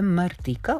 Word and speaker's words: Martika: Vidiš ko je Martika ili Martika sad. I tu je pitanje kako pravilno da izgovorimo. Gledala sam intervjue, ona samo sebe zove Martika, ok.